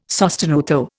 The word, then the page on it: sostenuto: Indian